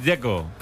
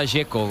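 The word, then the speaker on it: Džeko: Porque DZ, ¿cómo se pronuncia? – Dzeko. Bruno dice que es Dzeko. – Como tirando a J, Dzeko o algo así.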